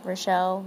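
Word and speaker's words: Richelle: Oh, the author's name is, I believe it's pronounced Rachel. It's R-I-C-H-E-L-L-E, Mead. That's her name, Rachel Mead.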